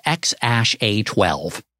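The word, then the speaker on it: X Æ A-12: By the way, I looked it up. It's pronounced X-A-12.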